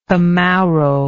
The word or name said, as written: camauro